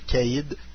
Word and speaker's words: Cade: Alors, en français, ça fait Caïd. – C'est ça, c'est Caïd. – Et ce Caïd Skywalker, c'est tout un Caïd. – Ben, ça s'écrit CAD. K2. – K2. – C-A-D-E.